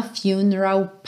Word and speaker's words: funeral: And our love become a funeral pyre. Funeral, atenção com esse U, porque ele tem o som igual da palavra você, you, ok? Funeral.